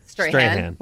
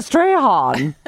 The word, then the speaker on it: Strahan: How do you say his name? – Strahan. – What I say? – Strahan. – Master Strahan.